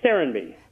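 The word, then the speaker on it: Serenbe: Is it Serenby? – Thanks, Dottie. Yes, it's Serenby.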